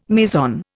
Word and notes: meson: Indian

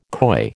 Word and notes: coy: American English

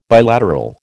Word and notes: bilateral: American English

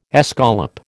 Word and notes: escallop: American English

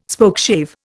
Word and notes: Spokeshave: Indian

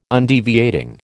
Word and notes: Undeviating: American English

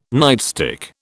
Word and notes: nightstick: British English

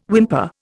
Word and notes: whimper: Indian

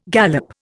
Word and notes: galop: Indian

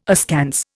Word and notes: askance: Indian

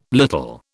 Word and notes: little: British English

